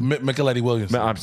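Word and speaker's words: Mykelti Williamson: Stoney Jackson, Robert Townsend, Michael T. Williamson. – Micheletti Williamson. – Michael T. Sorry. – That was the whitest way to say his name.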